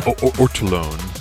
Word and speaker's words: Ortolan: Ortolan.